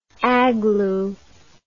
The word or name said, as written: aglu